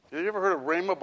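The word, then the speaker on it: Rhema (ῥῆμα): With a rough breathing mark, sort of like a silent H in front of it. Rhema.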